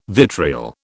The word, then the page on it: vitriol: British English